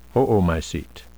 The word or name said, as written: oomycete